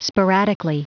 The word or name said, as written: sporadically